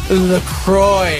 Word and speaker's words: LaCroix: So many people on the live line chimed in like, it's pronounced LaCroix. – Yeah, right, everybody, like, LaCroix.